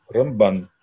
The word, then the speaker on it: Рамбан: А Рамбан говорят с ударением на второй слог. Рамбан.